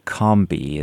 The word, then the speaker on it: Combahee: I'm going to pronounce it Combahee. Is that right? It's a wonderful story about Harriet Tubman. – Yeah, that's correct. – Okay, good. – You could Combahee, or it's more of a U sound, Combahee.